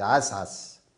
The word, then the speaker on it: عسعس: والليل إذا عسعس، بعض الناس يقرأ والليل إذا عسعس، يعني يفصل عس عنعس، يعني هي كلمة واحدة، هي فعل واحد، عسعس، فيبقى ننطق هكذا: والليل إذا عسعس والصبح إذا تنفس.